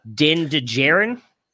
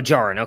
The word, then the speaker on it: Djarin: Is it Din Djarin? – Like Din Djarin. Yeah. – Oh, Djarin.